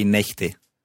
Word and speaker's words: Nächte: So the vowel sound changes in there. Die Nacht and zwei Nächte. – Nächte. Die Nacht and zwei Nächte. So the plural of a German word is formed by changing the sound.